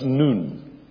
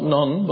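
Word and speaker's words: noon (νῦν): In the Greek, that is N-U-N, and it is pronounced noon. Not non, but noon.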